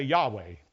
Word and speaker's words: Yahweh: Well, it's hard to pronounce that word. It's four letters. Y-H-W-H. If you put vowels in there, you could say Yahweh.